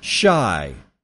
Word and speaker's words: Chi: CHI is pronounced shy, as in the nickname for Chicago, Shy Town. Really? Is it Shy. Thought it was Chai Town, but you know, okay, whatever.